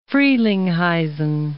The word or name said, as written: Frelinghuysen